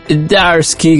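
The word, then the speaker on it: Zdarsky: Sí, de hecho sí, está Chip Darsky, que tiene una Z ahí que no se pronuncia.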